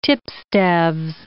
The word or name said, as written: tipstaves